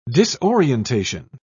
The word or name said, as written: disorientation